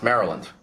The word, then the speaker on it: Maryland: Just like Maryland is pronounced Maryland.